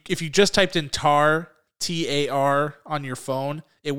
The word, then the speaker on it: Tár: which will be Tar. And yes, that's how it's pronounced, with the accent over the A.